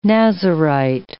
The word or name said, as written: Nazirite